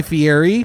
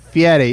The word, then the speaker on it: Fieri: You're not saying it right, though. – Fieri, yeah. – Guy Fieri. – Listen. – There is an R in that last name.